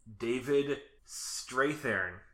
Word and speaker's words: David Strathairn: Next person in this movie is David Strathairn. – Mm-hmm. – Would be how it's properly pronounced.